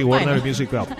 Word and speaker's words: Warner Music Group: Yo le comento, usted me corrige la pronunciación, Warner Music Group, ¿lo dije bien? – Sí, Warner Music Group.